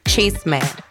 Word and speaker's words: chisme: So today's word is going to be chisme. So repeat after me. It's just two syllables, cheese, me. So think you're saying like the word cheese, but chis instead of cheese. So chis, me.